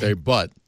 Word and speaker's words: debut: Pronounced debut. – Yeah, debut. Sorry.